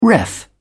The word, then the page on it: ref: British English